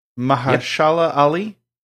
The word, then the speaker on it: Mahershala Ali: Attached to it, but they've got an actor, Mahershala Ali.